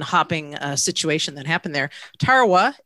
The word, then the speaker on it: Tarawa: Maybe you can help me with this pronunciation. Tarawa. Tarawa? – Yes, that's the name of a battle in World War II, right? – You got it.